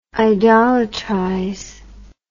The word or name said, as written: idolatrize